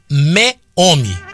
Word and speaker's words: Meomi: Então, se você quiser experimentar um vinho gostoso, chama Meome. Meome. M-E-O-M-I. Chama Meome. Meome. Como se falasse meio homem.